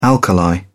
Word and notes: alkali: British English

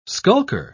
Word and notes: skulker: British English

